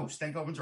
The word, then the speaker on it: Stankoven: And I think you've got number 4 and I've got him at number 5, and that's Logan Stankoven. – I'll tell you. – Am I saying it wrong? – No, Stankoven's right.